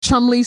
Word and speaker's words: Cholmondeley: is that is that like Chumley spelt Cholmondeley?